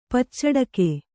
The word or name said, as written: ಪಚ್ಚಡಕೆ